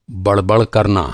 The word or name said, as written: बड़बड़